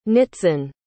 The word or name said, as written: nitzan